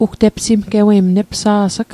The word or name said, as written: epsimgewei